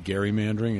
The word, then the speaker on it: gerrymandering: And you're going to see you still see a lot of that with the I call it gerrymandering. It's actually, everybody else pronounces it gerrymandering. I'm sorry, I'm a snob. – A hard G. It has to be a hard. Well, do you say GIF instead of JIF? – No, but it comes from Elbridge Gerry, who was the guy who did it.